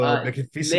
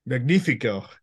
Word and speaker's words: Magnífico: How do you pronounce the character now? Leo Magnifico. Leo el Magnifico. – Magnifico. Yeah.